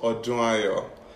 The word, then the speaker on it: Odunayo: So my first name is pronounced Odunayo. So it's a Yoruba name and it means year of celebration.